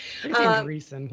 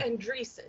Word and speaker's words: Andreessen: In fact, I guess Mark Andreessen, he's going, I think that's how you pronounce it. Andreessen? – Andreessen, yeah. – Andreessen, yeah.